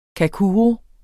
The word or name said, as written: kakuro